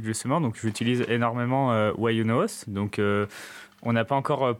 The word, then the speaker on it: YunoHost: Why You Know ça, ça s'écrit Y-U-N-O-H-O-S-T. Et du coup, c'est un jeu de mots qui est pour, euh, pour la phrase anglaise Why you no host, qui veut dire pourquoi est-ce que tu ne t'auto-héberges pas.